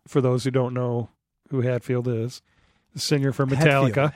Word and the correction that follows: Hetfield: Hetfield, not Hatfield. There's no McCoys fighting him. – It's Hetfield. – Spell it. – H-E-T, Het.